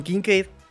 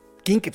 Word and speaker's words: Kincaid: Si, podemos decir Dalton, Ken, Newton, vamos a decir Dalton Kinket. A ver, niñita, lo estoy pronunciando muy bien. ¿Dalton Ah, Entonces, ¿cómo quieres que lo pronuncie? Como Enrique Garay. Dalton Kincaid. Dalton Kincaid. No, Kincaid, sí.